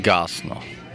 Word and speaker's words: Gosnell: So basically, this is how you say the name. It's Christopher Eron Gosnell. So G-O-S-N-E-L-L. The double L.